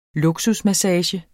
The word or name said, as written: luksusmassage